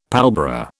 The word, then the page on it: pallbearer: British English